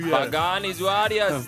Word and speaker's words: Pagani Huayra: Pagani Huayra.